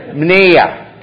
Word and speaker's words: mnea: It's not nyeh, okay? It's mnea, okay? That's how you pronounce it.